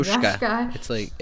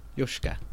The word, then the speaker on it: Yosuke: Yosuke. It's like Yoshi with a K-A instead of an I. Yosuke.